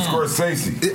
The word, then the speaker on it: Scorsese: Did I say it right? – Martin Scorsese. – That would be the hip hop version. Yeah, Martin Scorsese.